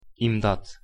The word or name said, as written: imdat